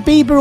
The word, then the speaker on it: Bieber: Is it Bieber or Beaver? – Bieber. Just like, it's having the bean.